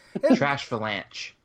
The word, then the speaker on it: Trashalanche: Its first attack, Tranchalanch? I can't even pronounce that. Trashalanche. Thank you.